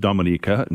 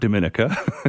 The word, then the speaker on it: Dominica: Well, for those who have never heard of Dominica, first of all, it's pronounced Dominica, not Dominica. So we'll get that cleared up.